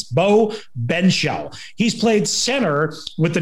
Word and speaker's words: Benzschawel: So I'm going to do the best we can and say it's Beau Benzschawel. Beau Benzschawel, yeah, is how I believe, and I remember his name being pronounced. Benzschawel, spelled Benzschawel.